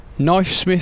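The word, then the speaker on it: Knyfesmyth: The Middle English pronunciation with the word K sounded may be the origin of the name Christmas Steps. Knifesmith sounds like Christmas.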